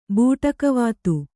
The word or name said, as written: ಬೂಟಕವಾತು